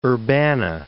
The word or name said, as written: Urbana